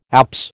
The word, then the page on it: apse: American English